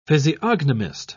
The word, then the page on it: physiognomist: British English